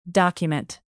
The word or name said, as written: document